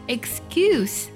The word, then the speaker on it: excuse: 要注意一下 excuse，这里呢它作为名词，它的 SE 的发音呢是思的音。